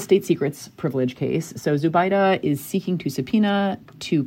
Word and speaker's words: Zubaydah: Okay, so the next case we wanted to debrief was United States versus Abu Zubaydah, whose name the Chief Justice seems to believe is pronounced Zubaydu.